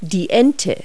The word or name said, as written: duck